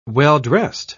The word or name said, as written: well-dressed